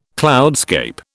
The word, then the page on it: cloudscape: British English